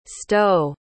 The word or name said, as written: stowe